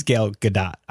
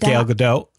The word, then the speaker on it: Gal Gadot: Yeah, she actually said her name in that trailer. And so I'm like, oh, it is Gal Gadot. I always thought it was Gal Gadot.